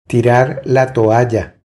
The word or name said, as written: Tirar la toalla